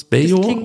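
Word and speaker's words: Beljo: Und zwar, ich hoffe, ich spreche den Namen jetzt richtig aus, Dion Drena Bello. Würdest du es Bejo aussprechen? Das klingt schon mal gut.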